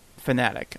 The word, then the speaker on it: Fanatec: I think it's Fanatic. I think that's the proper pronunciation.